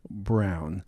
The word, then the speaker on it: Braun: It's spelled B-R-A-U-N, but they said I pronounced it wrong. It's Ari Brown.